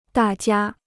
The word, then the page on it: 大家: dà jiā